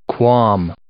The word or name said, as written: qualm